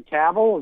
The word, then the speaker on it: Cavill: Ben Affleck, Henry Cavill, is that Superman? Is that how you say it? – Yeah.